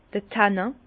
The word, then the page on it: d'r Tannin: Bas-Rhin; Herrlisheim